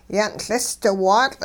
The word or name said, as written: yan'LisdiwaatL'